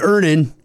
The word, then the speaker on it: Earnin: Earnin. – Earnin. – E-A-R-N-I-N is pronounced Earnin.